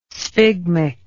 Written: sphygmic

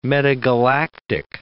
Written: metagalactic

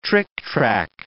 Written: trictrac

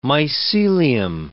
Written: mycelium

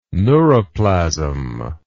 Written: neuroplasm

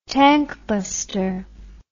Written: tankbuster

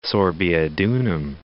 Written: Sorbiodunum

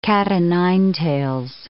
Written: cat-o'-nine-tails

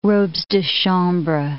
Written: robes de chambre

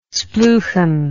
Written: spleuchan

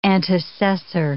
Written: antecessor

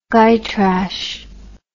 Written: gytrash

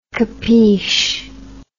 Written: capish

